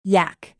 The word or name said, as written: yak